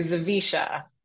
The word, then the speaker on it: Zawisza: So I went from Chapman to Zawisza.